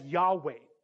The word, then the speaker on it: Yahweh: Is the consonants YHWH. We understand that be pronounced today as Yahweh.